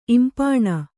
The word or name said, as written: ಇಂಪಾಣ